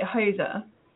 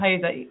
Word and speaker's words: Huyse: You say Huyse. Not easy. – Huyse. Okay. – Huyse. – Okay. – No, Huyse. Yeah, Huyse is fine too.